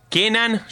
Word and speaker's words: Kenan: Kenan, ya viste, tipo el Ken.